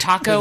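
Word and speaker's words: Taako: Are you naming your goddamn wizard Taco? – His name is Taco.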